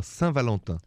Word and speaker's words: Saint Valentin: And in French, Saint Valentin. – Oh, Saint Valentin. – Saint, yeah. Did Saint, I say that right? – Saint Val- You said it really well. Saint Valentin.